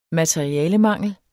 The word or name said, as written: materialemangel